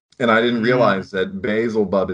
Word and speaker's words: Beelzebub: But just to say, like, that's the etymology of Beelzebub. We pronounce it Beelzebub, but it's Beelzebub.